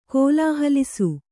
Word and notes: ಕೋಲಾಹಲಿಸು: kōlāhalisu

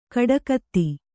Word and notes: ಕೊಡಗಿತಿ: koḍagiti

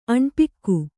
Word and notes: ಅಣ್ಪಿಕ್ಕು: Kannada

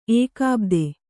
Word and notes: ಏಕಾಬ್ದೆ: Kannada

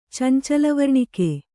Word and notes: ಚಂಚಲವರ್ಣಿಕೆ: cancala varṇike

